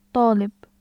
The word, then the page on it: ط: /t̩aːʔ/